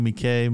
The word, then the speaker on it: Miike: Another Takashi Miike movie.